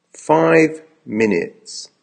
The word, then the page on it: 5 minutes: American English